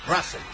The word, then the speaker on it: Russell: Perdón, ¿cómo se pronuncia? ¿Russell o Russell? – Russell. – Eso es para la gente de la mañana que hace el micrófono. Es Russell, especialmente para el enano.